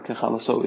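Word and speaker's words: ככלתו: The word kechalaso is written with a chaser, so it sounds like a kala, like a bride.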